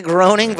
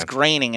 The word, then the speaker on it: Groening: It looks like groaning, but I've heard somebody say it, and it's graining, and it's like, oh, that is graining on my nerves.